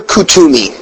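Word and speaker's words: Kuthumi: And then we talk about Master Kutumi, which is spelled Kut-Humi. That's how we pronounce it.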